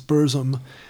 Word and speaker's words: Burzum: I think I pronounced it Barzum earlier, but it's Burzum.